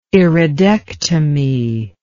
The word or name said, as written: iridectomy